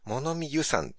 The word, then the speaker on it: 物見遊山: 山らしいです。遊じゃなくて。 – 遊山、あ、物見遊山って読むんですね。